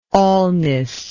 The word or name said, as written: allness